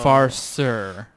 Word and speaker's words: Farceur: But Farceur is how Google said to pronounce it, which is spelled F-A-R-C-E-U-R, Farceur IPA.